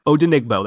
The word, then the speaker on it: Odenigbo: Odenigbo. That's it. When you just break it down to three syllables, it makes it so much easier.